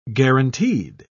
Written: guaranteed

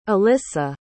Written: elissa

